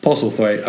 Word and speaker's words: Postlethwaite: Usually I struggle to say names, but Postlethwaite I've got down.